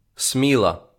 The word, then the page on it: Сміла: ˈsmilɐ; Ukrainian